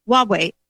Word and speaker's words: Huawei: Huawei sales director arrested in Poland over spying allegations.